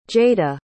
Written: jayda

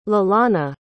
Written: Lalana

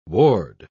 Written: ward

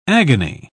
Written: agony

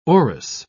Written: orris

